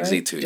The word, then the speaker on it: XZ2: XZ2, yes.